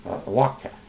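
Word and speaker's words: Lokteff: This is from Red Ice Radio, and this is Lana Kotcheff, and this is Things That Make You a Far-Right Extremist. This is from Red Ice Radio. And, Lana, I think I mispronounced that. Lana Wachteff.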